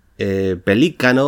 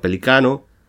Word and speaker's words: pelícano: pelícano o sin tilde, habría que pronunciarlo pelicano, polícromo o también policromo.